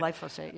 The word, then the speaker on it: glyphosate: The World Health Organization recently said that glyphosate, did I say that right? – Glyphosate, yeah.